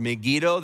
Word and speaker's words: Megiddo: Armageddon is a biblical term that is in reference to a geographic location in Israel called Megiddo, or Megiddo, they would pronounce it.